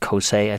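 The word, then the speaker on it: Kosé: a Japanese company called Kosei, I think it's pronounced.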